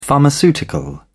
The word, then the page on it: pharmaceutical: British English